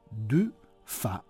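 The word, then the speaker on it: Dufay: È infatti dal testo stesso di questa messa che si verrà a conoscere la pronuncia corretta in 3 sillabe del suo nome Du Fa e la I con la Y.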